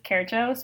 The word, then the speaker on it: Kercso: Alex with 2 X's, Kercho, spelled K-E-R-C-S-O, because I won't expect any of you to know how to pronounce that last name.